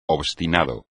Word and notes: obstinado: espagnol

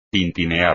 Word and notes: tintinear: espagnol

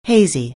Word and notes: hazy: anglais

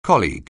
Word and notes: colleague: anglais

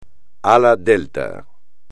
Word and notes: ala delta: espagnol

